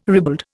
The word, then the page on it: ribald: Indian